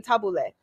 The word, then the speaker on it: tabbouleh: You cannot call me a terrorist and then go get fucking tabbouleh.